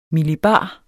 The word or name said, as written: millibar